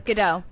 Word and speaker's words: Gadot: Gadot.